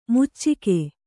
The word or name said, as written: ಮೋಚಿಕೆ